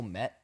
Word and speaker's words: Kmet: It's Cole Kmet. The K is silent.